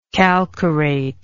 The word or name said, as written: calcarate